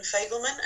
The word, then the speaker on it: Fagelman: My name is Helen Fagelman and it's spelled H-E-L-E-N-F-A-G-E-L-M-A-N.